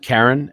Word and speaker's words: Charon: Is it Karen? – Yeah, it's pronounced Karen. I think that's the original. – I mean Charon.